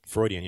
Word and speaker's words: Freudian: Freudian, yeah.